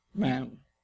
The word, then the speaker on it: Ma'am: And then after that, you must call her Ma'am. Rhymes with jam. Ma'am.